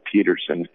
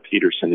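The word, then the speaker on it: Pedersen: Todd Peterson, and you have to spell it right. I have Danish lineage, so the way we spell Peterson is P-E-D, as in David, E-R-S-E-N, and you can get that book.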